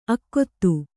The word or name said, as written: ಆಕೂತ